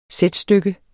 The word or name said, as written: sætstykke